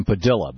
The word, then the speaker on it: Padilla: Yeah, he's actually asked, he says he pronounces his name Padilla, but yes.